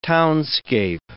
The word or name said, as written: townscape